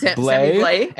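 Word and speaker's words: Blais: And then one other option, although they only play 6 times from the St. Louis Blues, is Sammy Blaze, who's back playing with Ryan O'Reilly and David Perrin. Are you laughing? I'm laughing at these pronunciations. It's Blay and it's David Perron.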